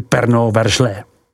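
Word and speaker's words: Pernand-Vergelesses: Alloxe Corton, vyslovuj Alloxe Corton, Lado X Serigny, vyslov Ladois Serigny, Pernard Vergelses, vyslovuj Pernod Verglès.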